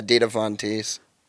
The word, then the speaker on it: Dita Von Teese: No, I was talking about Dita Von Teese or whatever. Is that how you say it? – Whatever. Yeah, Dita Von Teese.